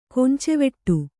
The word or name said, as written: ಕೊಂಚೆವೆಟ್ಟು